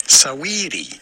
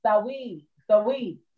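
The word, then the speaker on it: Saweetie: It's almost like saying sweetie, but there is a little A sound before. Saaaweetie. – Saaaweetie. – You actually pronounce the A. Saaaweetie.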